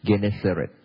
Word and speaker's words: Gennesaret: Gennesaret.